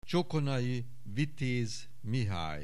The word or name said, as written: Csokonai Vitéz, Mihály